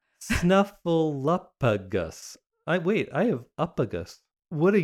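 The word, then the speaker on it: Snuffleupagus: Snuffleuppigus. Okay, I've been saying it correctly, I guess. But it's weird because it has a F-L-E-U in the middle. Like flu.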